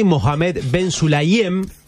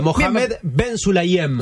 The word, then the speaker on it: Mohamed Ben Sulayem: Mohamed Ben Sulayem, su nombre. – Aquí árabe. – Bien lo pronunciaste, ¿eh? – ¿Sí? Me encantó. Muy bien, vamos de vuelta. Mohamed Ben Sulayem.